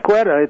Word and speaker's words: Quetta: Well, I'm Am I even saying it right? – Yes, Quetta.